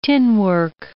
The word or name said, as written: tinwork